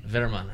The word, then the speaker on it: Vermann: And the owner, which I can pronounce his first name, Dennis, but his last name is German, so I'm going to show and see how he... – Vermann, all right.